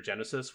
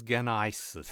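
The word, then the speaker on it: Genisys: Wait, is that how you pronounce that? I thought it was Genesis.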